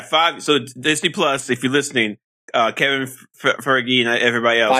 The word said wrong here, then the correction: Feige: Feige. You got to get his name right when you're pitching. – Kevin Feige.